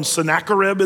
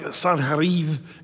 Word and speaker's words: Sennacherib: One of the things that's largely lacking, by the way, in history is the message of, remember how we call him Sennacherib in the Bible? It's really more like Sanherib is the way you say his name.